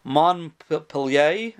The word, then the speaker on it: Montpellier: Min Hahar is referring to a place called Montpellier, or in French, it's called Montpellier, or something like that, which is a city in southeast France. And uh, Mon is M O N T, the T is silent um, and in that sentence at least, in that uh, word.